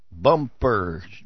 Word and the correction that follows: Bumbershoot: No, there's a B, not a P.